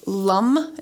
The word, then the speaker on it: LAM: And so the bija mantra of the root chakra is LUM. It's spelled L-A-M, pronounced LUM, as the note is LUM.